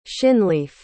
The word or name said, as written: shinleaf